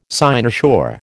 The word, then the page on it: cynosure: American English